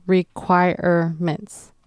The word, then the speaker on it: requirements: The last word to watch out for when you're pronouncing it is requirements, and that's a 4-syllable word. And that R-E in the middle, you want to pronounce that as R, so requirements.